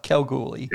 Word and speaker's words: Kalgoorlie: She might be the richest lady in Kalgoorlie, or whatever. – You were close. Whatever this you is. Got the last end of it right. – Kalgoorlie. – Don't overpronounce the R.